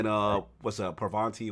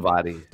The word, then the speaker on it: Parvati: Vicar Max, and then Parvanti, whatever. I always mispronounce it. Parvati.